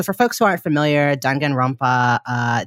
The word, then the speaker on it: Danganronpa: You brought to us the Danganronpa. Am I saying that right? – Yes.